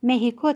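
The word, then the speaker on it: México: مکزیک را به شوخی مثل اسپانیایی‌ها مهیکو تلفظ کرده بود.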